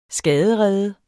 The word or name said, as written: skaderede